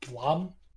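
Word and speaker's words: Blame!: and there's also this manga called Blame. It's like Blame with an exclamation mark. I think it's actually supposed to be pronounced Blom.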